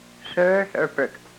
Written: srrhlrprk

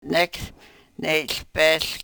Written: nek ne-hlpehl

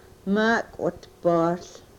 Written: maakwitpohl

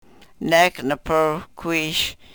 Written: nek ne-prwkwish

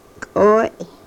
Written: kooy

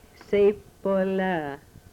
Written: sepolah

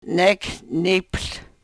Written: nek n-iphl

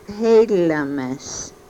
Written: helomes

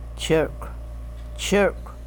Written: chrkw

